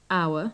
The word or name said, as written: hour